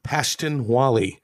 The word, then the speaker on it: Pashtunwali: Is Pashtunwali, is that how you pronounce it? – Yeah. – Pashtunwali.